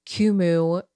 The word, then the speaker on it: Qumu: They go by the username Kumu Music. We think that's how it's pronounced. So it's spelled Q-U-M-U Music.